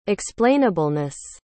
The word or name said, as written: explainableness